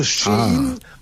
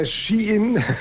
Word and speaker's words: Shein: Ja, also Shein oder Shein,